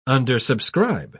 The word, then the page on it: undersubscribe: British English